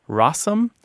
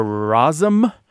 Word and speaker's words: rosin: The reason I said we'll talk about rosin in a second is some folks say rossum or rosum or uh-huh. rosing. So, and you'll find all of these pronunciations throughout the American South and Midlands and that sort of thing.